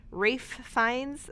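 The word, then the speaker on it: Ralph Fiennes: Rafe Fiennes.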